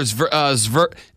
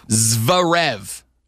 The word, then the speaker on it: Zverev: Grand Slam champion Novak Djokovic just straight up left his Australian Open semifinal match against Alexander Zverev. It's a Z and a V next to each other. That's impossible.